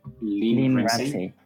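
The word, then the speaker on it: Lynne Ramsay: Lynn, Ramsey.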